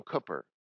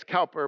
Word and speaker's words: Cowper: The hymn you sang right before I began to preach was written by a man named William Cooper. It looks like it's Cowper, but it's pronounced Cooper.